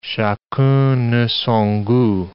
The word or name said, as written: chacun a son gout